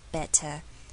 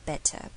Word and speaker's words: better: 像是我之前会说，比如你讲 better 的时候，我们做美式发音会把 TT 变成 DD 的声音，变成 better，但是在英式发音的话 TT 就不会变音，就是维持一样的 TT 的声音，所以就是 better better。